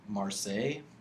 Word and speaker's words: Marseille: I'm looking forward to the Netflix original series, Marseille.